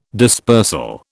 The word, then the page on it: dispersal: British English